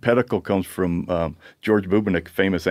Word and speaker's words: pedicle: But Anthony Bubinick didn't coin the phrase pedicle, but he told us how to pronounce it, pedicle. He says it rhymes with medical.